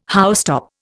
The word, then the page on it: housetop: Indian